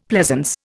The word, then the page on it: pleasance: Indian